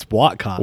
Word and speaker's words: Whatcott: Is it Whatcott or Whatcott? Like, is there a proper way to say it? – I've always grown up, it's Whatcott.